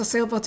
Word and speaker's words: silver: Like the silver dining s e シルバーの発音がちょっと難しいですね。ソーバーみたいに聞こえますが、シルバー。